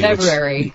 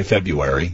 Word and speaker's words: February: It sounds like you're pronouncing that R that most of us ignore. I say February. You say February.